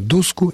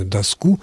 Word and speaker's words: доску: Практически во всех формах слово «доска», я имею в виду формы косвенных падежей, единственного числа, слово «доска» допускает ударение как на корне, так и на окончании. Поэтому мемориальную доску и мемориальную доску, оба варианта правильны.